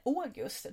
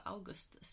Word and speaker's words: August: Men August kommer från Augustus. och ska uttalas som Augustus.